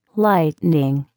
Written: lightning

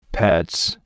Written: pets

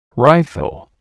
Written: rifle

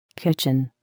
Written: kitchen

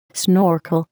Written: snorkel

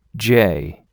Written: jay

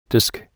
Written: disc

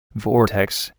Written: vortex

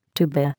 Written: tuba

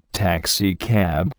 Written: taxicab

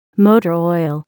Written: motor oil

